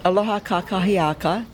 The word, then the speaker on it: aloha kakahiaka: Aloha kakiha. Gotta pronounce it correctly. Aloha kakahiaka.